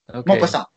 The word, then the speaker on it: Maupassant: Okay. Maupassant. Sorry, yeah. I'm pronouncing his name every time wrong.